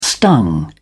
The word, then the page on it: stung: British English